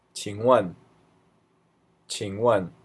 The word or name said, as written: qingwen